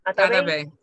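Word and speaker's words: Atabey: Atabi. Atabi? Is that how you pronounce it? – Atabe. – Yes, sorry.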